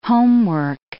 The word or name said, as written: homework